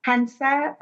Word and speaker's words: Hansa: If you type my name in the search box, Hansa, which is spelled H-A-N-S-A,